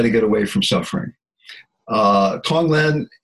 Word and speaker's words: Tonglen: Now, you also talk about a practice of Tonglen.